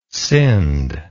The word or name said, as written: Sind